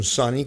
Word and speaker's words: Sunny: Es un Sunny con U, que se escribe, pero se pronuncia prácticamente igual que el Sunny con O.